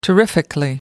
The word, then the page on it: terrifically: British English